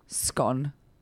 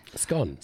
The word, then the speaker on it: scone: I just thought, oh, that's interesting that 51% of Britons pronounce the word scone just as I've said it. – Scone. – Scone. – Like gone. – Right? No. And then 42% rhyme it with bone.